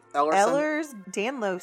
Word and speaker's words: Ehlers-Danlos: Ehlers-Danlos syndrome.